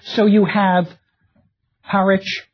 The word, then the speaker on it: Harwich: Or the suffix which, pronounced without the W now, like Norwich or Harwich, in the Scandinavian settled communities, this ending would have been vik or wick.